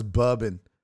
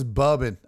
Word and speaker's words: bourbon: Uh, no, no, look, it says bourbon. Nobody says bubbin.